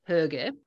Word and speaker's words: hygge: And actually, how do you say, you know, when you can't have a hoog, you can have a hygge.